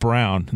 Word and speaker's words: Braun: That uh, Braun is pronounced Brown.